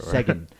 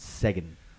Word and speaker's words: Segen: Sagan. It's spelled S-E-G-E-N. – Okay. – And it sounds like second when they say it. – Yeah, it does. – So it's Sagan.